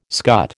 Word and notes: scut: American English

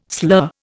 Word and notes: slur: Indian

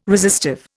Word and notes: resistive: Indian

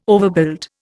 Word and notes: overbuild: Indian

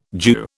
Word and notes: Jew: British English

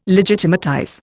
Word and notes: legitimatize: Indian

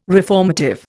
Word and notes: reformative: Indian

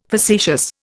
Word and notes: facetious: Indian

